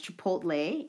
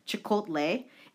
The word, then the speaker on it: Chipotle: I think some of you saw in the news, JK mispronounced Chipotle in the January 23rd Bangtan Bon as Chikotle.